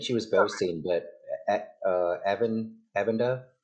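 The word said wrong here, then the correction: Aviendha: how do we say her name again? – Avienda.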